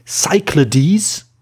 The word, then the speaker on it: Cyclades: Das Spiel ist äh, Kyklades oder Kyklades auf Griechisch, Kyklades auf, auf Deutsch oder wenn man es auf amerikanisch aussprechen will, Cyclades.